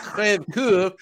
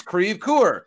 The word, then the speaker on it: Crèvecoeur: And it's not Crèvecoeur, it's Crèvecoeur. And so,